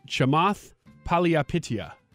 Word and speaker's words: Chamath Palihapitiya: His name is, am I pronouncing this right? Chamath Palihapitiya. Is that correct? – Mm-hmm. – All right. Thank you for that confirmation.